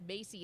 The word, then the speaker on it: Maci: M-A-C-I, pronounced Macy.